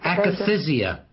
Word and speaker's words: akathisia: But, anyway, she was an interesting case, and she got to the point with akathisia. Is that how you pronounce it? Tardive akathisia? – Akathisia. – Yeah, akathisia. Yeah.